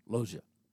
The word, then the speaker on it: Lozen: But to expand resources, there were some women warriors, the most notable L-O-Z-E-N, silent N, Lozia.